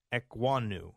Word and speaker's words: Ekwonu: Is that right? Is that how you say it? The offensive lineman out of NC State? – Akeem Ekwonu. – Yeah, Ekwonu. Okay. – Ekwonu. Yeah, Ekwonu. – I think so.